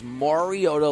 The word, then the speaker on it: Mariota: It is Mariota like Mario Kart.